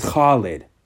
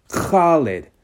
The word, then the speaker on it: Khaled: And I have, you know, some knowledge of Arabic, and I know that the KH is more of a like you got to use your like throat and like garble a little bit, like like DJ Khaled, his name is Khalid. Khalid.